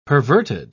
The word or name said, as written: perverted